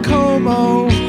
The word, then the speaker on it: Como: You should be on Cuomo with Clooney. Should be shared with you. – Little fossil, I think it's pronounced Como. – Okay, so you all thought I was talking about disgraced New York brothers.